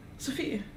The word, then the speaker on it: Sofie: Sofie.